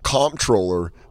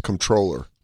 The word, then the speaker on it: comptroller: Comptroller isn't comptroller. It's controller.